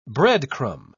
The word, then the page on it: breadcrumb: British English